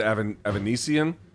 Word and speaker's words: Avanesyan: David Avanesyan? – That's correct.